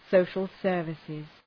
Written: social services

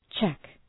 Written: cheque

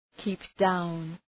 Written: keep down

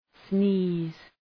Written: sneeze

